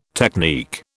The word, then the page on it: technique: British English